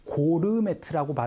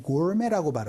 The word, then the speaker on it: gourmet: 이거를 고르메트라고 발음하지 않고 gourmet라고 발음하니까 알아두시면 좋아요.